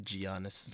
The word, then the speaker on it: Giannis: I always laugh when people say Giannis. – Giannis, sorry, Giannis. Giannis. – That's how it's spelled. – but I'm not supposed to say it like that.